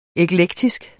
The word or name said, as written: eklektisk